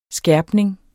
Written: skærpning

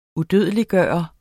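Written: UDØDELIGGJORTE

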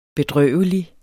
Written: bedrøvelig

